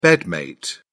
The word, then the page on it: bedmate: British English